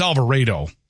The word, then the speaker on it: Alvarado: 1:49, severe thunderstorms along a line from Alvarado, which I love the computer voice, Alvarado. No, it's Alvarado. Okay.